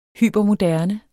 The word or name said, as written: hypermoderne